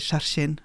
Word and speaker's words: Hjerkinn: Som vi får vite skal uttales Skjerkinn. Skjerkinn.